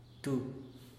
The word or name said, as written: tu